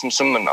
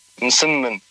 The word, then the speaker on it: Msimmen: Auf Marokkanisch spricht man das Msimmen aus. – Nochmal? – Msimmen. Msimmen. Genau, Msimmen.